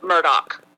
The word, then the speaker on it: Murdaugh: this is Mr. Murdoch.